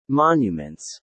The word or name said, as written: monuments